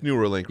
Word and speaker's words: Neuralink: Am I saying it right? It is Neuralink, right? – Yeah.